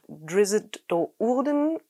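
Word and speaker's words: Drizzt Do'Urden: Driss Do'Urden. – Meine Hobbys sind zeitintensiv und teuer. Ich bin ja selber schuld. – Gut, der Autor der Romane, R.A. Salvatore, sagt Driss Do'Urden und ich glaube, es gibt dutzende verschiedene Varianten.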